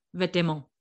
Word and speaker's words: vêtements: You say vêtements. – Yeah. Which is you don't pronounce half the letters.